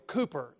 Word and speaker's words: Cowper: Some of you know the name William Cooper, spelled Cowper, C-O-W-P-E-R, pronounced Cooper.